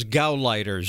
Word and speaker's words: Gauleiter: any more than Hitler's local Gaultiers. – Now, that word there was Gauleiters. She mispronounced that.